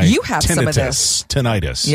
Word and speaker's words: tinnitus: And you'd even look up the definition and the accent is on first syllable, tinnitus.